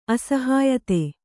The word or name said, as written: ಅಸಹ್ಯತೆ